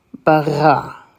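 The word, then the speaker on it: bara (בָּרָא): And for the next syllable, it is a resh, a kamatz, and an aleph. Remember, the aleph is silent. Ra. So putting it all together, it's bara.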